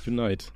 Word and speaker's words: Unite: Unit wäre ohne E am Ende. Unite, ja.